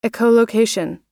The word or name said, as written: echolocation